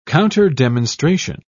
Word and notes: counterdemonstration: British English

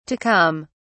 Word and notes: to come: British English

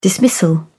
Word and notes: dismissal: British English

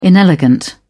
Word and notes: inelegant: British English

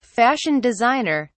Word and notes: fashion designer: British English

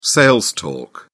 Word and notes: sales talk: British English

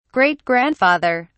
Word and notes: great-grandfather: British English

